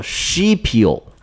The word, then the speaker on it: Scipio: 拉丁语这个名字读作 Scipio，一共就六个字母。